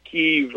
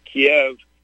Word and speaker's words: Kyiv: And, of course, Biden was in Kyiv, or I pronounce it Kiev, and he's up there on a podium, you know, and...